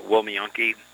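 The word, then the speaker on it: Łomianki: And then this afternoon we traveled to Wilmianki, which is spelled with an L in English but pronounced with a W.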